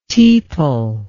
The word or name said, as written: tepal